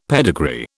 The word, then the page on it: pedigree: British English